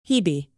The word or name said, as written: hebe